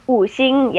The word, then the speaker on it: Wuxing: The Wuxing. – Wuxing, yes.